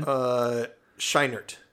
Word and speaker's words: Scheinert: Is that Schwinert? How do you pronounce his last name? – Scheinert. – Scheinert. Daniel Scheinert.